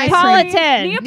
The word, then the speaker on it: Neapolitan: What flavor makes Napoleon. Is that right? Am I saying that right? – Napoleon. – Neapolitan. – Neapolitan.